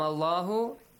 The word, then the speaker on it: الله: نَحْنُ نَرْزُقُكُمْ وَإِيَّاهُمْ وَلَا تَقْرُبُوا الْفَوَاحِشَ مَا ظَهَرَ الله. Pronounce the الله heavily.